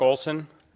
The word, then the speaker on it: Olson: My name is Mark Olson. I was told to spell that for everybody. It's Mark with a K, O-L-S-O-N.